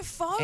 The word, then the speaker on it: faux: Words with silent X. Is silent in the words faux and faux pas. – Oh, yeah, faux.